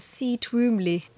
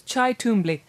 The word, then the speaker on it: Cy Twombly: Yes, I always thought it was Cy Twombly. – It's Chai Twombly.